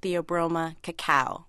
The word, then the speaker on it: Theobroma cacao: The scientific name is Theobroma cacao, which actually means food of the gods.